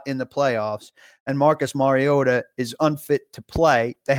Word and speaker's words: Mariota: In Nashville, on my radio show, the Midday 180 in particular, we just crush people who don't say Mariota, who say Mariota.